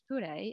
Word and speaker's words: pure: But what you might not know is that the same word is pronounced pure in Māori, and it has a very significant spiritual meaning in the Māori language.